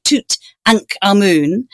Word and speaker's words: Tutankhamun: But if you're saying it properly, you'd say Tutankhamun. And it's because he was renamed after Amun, who was one of the great powerful gods of ancient Egypt, rather than Aten, who was the god that his father worshipped, who was the great kind of sun disk.